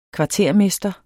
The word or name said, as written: kvartermester